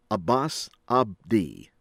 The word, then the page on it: Abbas Abdi: American English